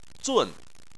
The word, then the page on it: zun: 4